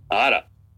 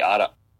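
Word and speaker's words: Arak: Ada. It's A-R-A-K, Ada.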